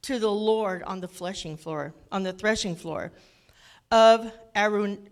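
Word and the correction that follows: Araunah: I can't never say his name. It's called Arauna, the Jebusite.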